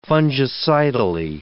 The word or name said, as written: fungicidally